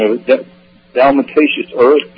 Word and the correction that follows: diatomaceous: Diatomaceous earth, yeah.